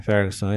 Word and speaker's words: Ferguson: O de Ferguson, eu não sei como pronuncia. É, Ferguson, é isso mesmo.